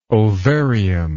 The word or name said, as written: ovarium